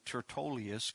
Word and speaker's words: Tertullus: That's how you say his name, Tertullius, kind of a odd name.